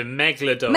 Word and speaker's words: megalodon: meg- how do you say it? – The megalodon. – Megalodon, that's it.